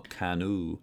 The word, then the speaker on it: Caanoo: I guess that's how you'd pronounce it, but they they doubled all the vowels in that name, so it's spelled C-A-A-N-O-O. – Oh, Canoe.